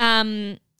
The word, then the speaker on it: Yosemite: Yosemite.